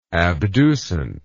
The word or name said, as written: abducent